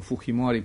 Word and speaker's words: Fujimori: Tada je bio Fujimori, odnosno Fujimori po španskom izgovoru, protiv kandidat poznatom piscu Mario Vargasu Ljosi.